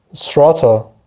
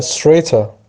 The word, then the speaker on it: Strata: همچنین جمعش هم اگه دقت کنید توی مثال ها Strata یا Strata جفتش هم تلفظ میشه.